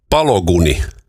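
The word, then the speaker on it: Balogun: Eli Donald on soittanut mitä ilmeisimmin FIFAn johtoon tästä Yhdysvaltain Folarin Balu, miten tämä lausutaan? Pologuni. Folarin Pologuni. No niin, amerikkalainen.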